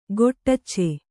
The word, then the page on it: ಗೊಟ್ಟಚ್ಚೆ: goṭṭacce